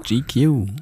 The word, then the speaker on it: GQ: Von der Zeitschrift wie wird das ausgesprochen? GQ? – GQ.